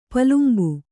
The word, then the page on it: ಪಲುಂಬು: palumbu